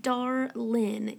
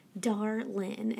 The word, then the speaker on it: Darlin: I mean, it's literally Darlin. You will not believe how many people have called me Daryl, Daryline, Daryline. I'm like, you're so close, Darlin. And my mom, even growing up, she would say, you know, I don't care what people say.